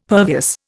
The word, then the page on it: pervious: Indian